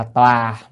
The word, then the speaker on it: tòa: À, là tòa mà thường chúng ta nên đọc là tòa, tòa là cái chỗ ngồi, còn tọa là ngồi xuống đó. Thì nên đọc là tòa thì hay dùng hơn.